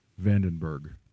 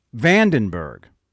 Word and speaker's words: Vandenberg: How do you pronounce his last name? – Vandenberg. – Vandenberg.